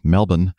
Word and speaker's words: Melbourne: Melbourne, I guess I'm supposed to pronounce it for the uh, one that's in Australia. Melbourne, as opposed to Melbourne, which is in Florida.